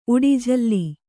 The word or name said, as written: ಉಡಿಝಲ್ಲಿ